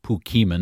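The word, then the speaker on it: Pokémon: I am fully aware it's pronounced Pokemon.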